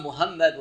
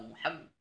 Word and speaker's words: Muhammad: Bien sûr, on ne dit pas Muhammad, voilà, Muhammad. Donc, on dit, il faut bien le prononcer. Le ha, mim, avec doublement.